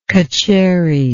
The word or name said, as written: cutcherry